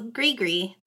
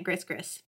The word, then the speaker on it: gris-gris: Though, Grigri, that I had to Google how to pronounce that because I was calling it Gris-Gris.